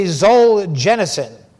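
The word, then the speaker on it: Solzhenitsyn: It's supposed to be Zolzhenitsyn. That's how I looked it up and I listened to it. That's what it said. That was close anyway.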